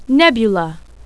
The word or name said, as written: nebula